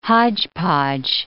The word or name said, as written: hodgepodge